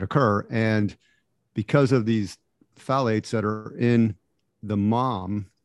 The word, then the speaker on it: phthalates: So I was listening to this podcast about a month or two ago, and they had a doctor on, and they're called phthalates.